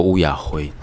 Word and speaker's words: 雨夜花: 欢迎来到三高公虾会，公虾会，我们讲一些美丽与哀愁，讲一些我们需要文学的理由。我们今天要录的是一首台语歌《雨夜花》，闽南语当然读作乌雅辉。